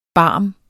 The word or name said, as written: barm